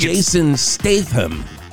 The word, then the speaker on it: Jason Statham: And Jathan Statham, did I say that correctly, guys? – I think you were way off. – I think Jason Statham. – Oh, I always thought it was Jason Statham.